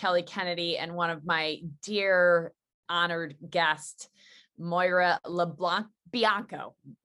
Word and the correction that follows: Moira Lo Bianco: Moira Lo Bianco. Did I say it right? – Moira Lo Bianco. That's perfect.